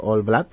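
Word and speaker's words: Old Blood: Por lo menos a partir del All Blood. ¿Lo he dicho bien? Del All Blood. Es muy recomendable. – Muy bien.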